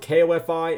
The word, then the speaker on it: Ko-fi: Well, it might be Ko-fi, but it's spelled K-O-F-I, and that's weird, so I say it Ko-fi.